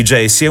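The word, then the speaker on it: Djesse: Il titolo di Jaycee è una pronuncia fonetica delle sue iniziali, JC, Jacob Collier, un modo per dire che quest'opera è la summa del suo universo artistico.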